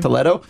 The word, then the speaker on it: Toledo: Toledo? – Yeah.